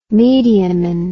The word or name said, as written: mediaman